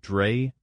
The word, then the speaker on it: Drais: And according to them, it was a German inventor named Carl von Drais, D-R-A-I-S. I guess that'd be sort of a French pronunciation, Drais. – Well, if it's von, then it would be Germanic, which would be Drais.